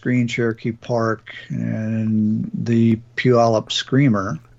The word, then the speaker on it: Puyallup: This is from Lisa, and Lisa moved to Puyallup, as she was taught to pronounce it, due to the wind blowing. – That is correct.